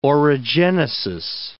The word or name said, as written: orogenesis